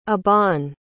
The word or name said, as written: Aban